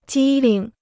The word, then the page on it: 机灵: jī ling